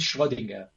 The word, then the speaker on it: Schrödinger: Entonces, este, este hombre eh, dice una cosa muy interesante, Erwin Schrödinger, eh, pronunciado así en alemán,